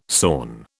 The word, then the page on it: sawn: British English